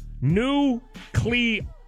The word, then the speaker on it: nuclear: Stop saying nuclear like you're George Bush. It's nuclear. There's no U after the C.